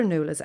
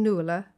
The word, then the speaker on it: Nuala: I live in Galway, but I'm from Dublin originally, and we Dubs completely ignore the ua, that neat diphthong wedged in the centre of the word. Instead of saying Nuala, we say Nuala, to rhyme with hula, as in hula hoop, as I often need to explain to non-Irish people.